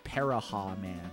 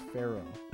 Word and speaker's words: Pharaoh: But as a kid, I pronounced it as Paraha Man. Because I could not read that word. So I was just like, Paraha Man. So I think, how did I fix that pronunciation? I think I read it in the presence of my father, and he was like, it's Pharaoh.